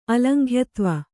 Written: ಅಲಂಘ್ಯತ್ವ

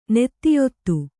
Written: ನೀತಿಯುತ

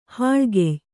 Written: ಹಾಳ್ಗೆಯ್

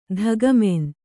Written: ಧಗಮೆನ್

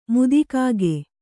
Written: ಮುದಿಕಾಗೆ

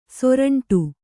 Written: ಸುರುಂಟು

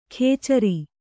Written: ಖೇಚರಿ

